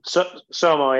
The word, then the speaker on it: Sommo: Sommo, yeah. The and joke is it rhymes with FOMO. So Sommo.